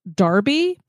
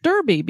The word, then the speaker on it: Derby: Oh, the best one we did is we did a town in Europe that's called Darby, but it's spelled Derby. So through the whole episode, we kept calling it Derby because if you're E-R-B-Y, that's what I'm going to say.